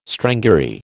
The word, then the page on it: strangury: American English